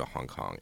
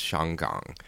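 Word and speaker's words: Shanggang (香港): Like, even Hong Kong is in, well, I guess in Cantonese it's closer to Hong Kong. In Mandarin it's Shanggang.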